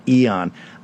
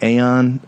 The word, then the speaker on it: eon: I've been thinking about this a lot, and I understand the Cambridge pronunciation is eon. I've always called it aeon. I think I may be going back to my pronunciation and the hell with Cambridge on this, but I digress.